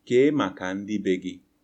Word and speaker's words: ke maka ndi begi: So if someone said to you, ke maka ndi begi, what does that mean, and what would you reply? – Ke maka ndi begi. – Yes. – Did I say it correctly? – Yes, that's correct.